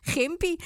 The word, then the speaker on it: Gympie: Zeg je nou Gympie? – Ja, inderdaad. Gympie. Het zal Gympie heten, denk ik. Dat moet natuurlijk wel op zijn Engels uitgesproken worden.